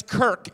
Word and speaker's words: Kirk: My last name is Kirk. It rhymes with jerk.